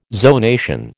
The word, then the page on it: zonation: American English